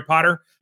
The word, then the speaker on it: Potter: My name is Jerry Potter. Yes, it rhymes with the boy wizard, and I'm the host of the show.